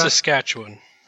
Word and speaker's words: Saskatchewan: Can you help me pronounce that, please? How do you say that? – Saskatchewan. – Perfect.